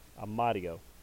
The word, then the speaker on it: Amadio: And Michael Amadio. and even though I'm slightly mispronouncing that, it's a D, not an R. Amadio.